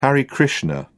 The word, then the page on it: hare krishna: British English